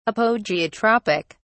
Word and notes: apogeotropic: British English

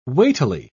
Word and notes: weightily: British English

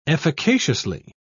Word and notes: efficaciously: British English

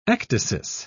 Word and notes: ecdysis: British English

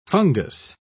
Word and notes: fungous: British English

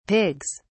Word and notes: pigs: British English

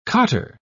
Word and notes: cottar: British English

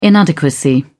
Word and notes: inadequacy: British English

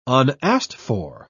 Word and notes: unasked-for: British English

